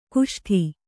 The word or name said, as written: ಕುಷ್ಟೆ